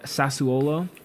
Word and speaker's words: Sassuolo: Sassuolo.